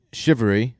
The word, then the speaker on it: Cheverere: Shivery, is how you say my last name. – There are four non-consecutive E's. – C-H-E-V-E-R-E-R-E.